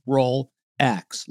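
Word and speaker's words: Rollx: It's Rolex, but it's spelled R-O-L-L space capital X, Rolex, like the watch.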